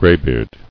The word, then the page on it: Graybeard: gray·beard